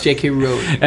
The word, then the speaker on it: J.K. Rowling: J.K. Rowling. – Now, are you sure that's the British pronunciation or the American pronunciation? – I'm sure it's J.K. Rowling.